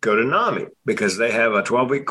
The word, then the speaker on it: NAMI: There's something called National Alliance Mental Illness, NAMI, NAMI or NAMI, depending on who, you know, your preferred pronunciation. National Association. – NAMI.